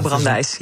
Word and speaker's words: Brandeis: Spreek je dat zo uit eigenlijk, Brandeis? – Ja, goedenavond. Dat spreekt u helemaal goed uit. Ja, bureau Brandeis.